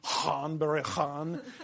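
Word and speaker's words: Ghân-buri-Ghân: But the woodman, gone, bury, gone, or as I believe it's supposed to be pronounced, and I really can't very successfully, Han Bury Han. The GH is like a voiced sound.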